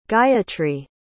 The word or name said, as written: gayatri